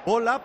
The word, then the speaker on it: Boya: Bola, pero se escribe Boya.